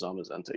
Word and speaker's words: Zamazenta: I don't know how you pronounce it, but we're gonna say Zamazenta. – It was Zamazenta.